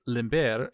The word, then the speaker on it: limber: Locals supposedly began recreating the treat and calling it limber, a nod to the Spanish pronunciation of Lindbergh's last name.